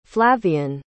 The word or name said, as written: flavian